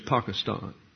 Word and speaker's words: Pakistan: Correctly pronounced over there as Pakistan.